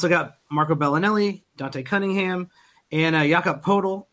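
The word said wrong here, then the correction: Pöltl: in the Raptors trade. I don't know how to say his name either. – And Lonnie Walker, the, it's like a cat does. What does a cat? What's what? What noise does a cat make, Mike? – Uh, Purtle. – There you go.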